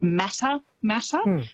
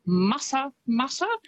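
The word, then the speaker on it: Matamata: Quite often, because of how it's spelt, people will pronounce it Mata Mata, but it's Mata Mata, which is, I think, a lovely way to say it.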